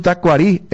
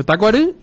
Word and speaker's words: Tacuarí: La pronunciación del club Tacuarí, el acento debe ser en el idioma guaraní, dice.